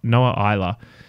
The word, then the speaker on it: Isla: And that song will be called Euphoria, spelled with an F, by Noah Isla. And that is spelled I-S-L-A, like island.